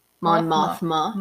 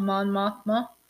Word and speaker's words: Mon Mothma: I think it's Mothra. Mon Mothra? – Mon Mothra. – It's Mothma. Mon Mothma. – Mon Mothma.